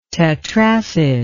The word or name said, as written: tetracid